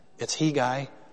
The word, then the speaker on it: Hegai: Side note, by the way, I wanted to make sure I knew how to pronounce that name, Hegai. It's Hegai, and he's a eunuch.